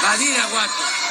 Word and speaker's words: Badiraguato: Un camino de 140 kilómetros de Badiguarato. Badiguarato, Badiguarato. Badiraguato. guato, Querido Badiraguato. De Guato! ya me estaba yo camucando otra vez. De Badiraguato, Guato!